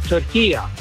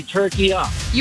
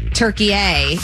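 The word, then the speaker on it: Türkiye: As far as the pronunciation of turkey, it's very easy. At the end is a short A. It's not a long A. In the Arabic language, it's called turkiya. So you could say turkiya. – You and I are making it a hard A. Turkey A, and it's turkiya.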